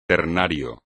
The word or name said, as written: ternario